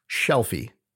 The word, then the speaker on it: Shelfie: Yeah, it's Library Shelfie Day. No, I am not saying that wrong. Shelfie, like a library shelf.